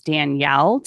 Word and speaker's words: Danielle: But even then, if I read your name, right, if you're trying to decode somebody's name, mine is Danielle. Typically, it's spelled the way it's pronounced, although some people spell it like Daniel.